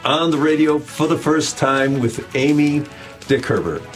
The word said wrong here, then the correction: Kamie: That's Kamie. That's like Amy with a K.